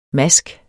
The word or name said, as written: MASKENS